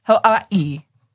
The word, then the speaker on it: Hawaii: Okay, so I volunteered with one of our volunteers is a native of Hawaii. – Uh-huh. – Hawaii. – And that's how you say it. Hawaii. Like something like, yeah. – With a little bit of a V? – Yes.